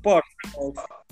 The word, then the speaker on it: Portsmouth: Portsmouth Mientras comenzaba a escribir las novelas que lo harían famoso y tratar de vivir como médico, retomó su pasión por el deporte.